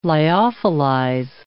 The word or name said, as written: lyophilize